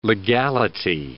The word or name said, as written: legality